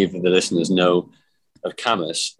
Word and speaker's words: Cambois: partly because nobody knows how to say it, because it's spelled Cambois, as in, like, it sounds French. – It does. I thought it was like framboise. So when I first moved, I was like, oh, yes, Cambois. Yes.